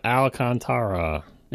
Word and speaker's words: Alcantara: You never have to say it out loud when it's in car magazines, and all of a sudden you're faced with this word. You got to do it a syllable at a time. Alcantara.